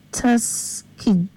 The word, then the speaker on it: Tuskegee: Our message says, one example is the white man using science to harm is Tuskegee experiment, if I'm saying it correctly.